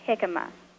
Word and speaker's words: jicama: So I wanted to ask you about a vegetable that's pretty ubiquitous here in Central Texas, and it's spelled J-I-C-A-M-A, and I've heard it pronounced jicama. – That's right. Yes, it is pronounced jicama.